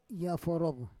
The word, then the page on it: yaforov: Roon